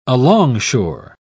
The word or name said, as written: alongshore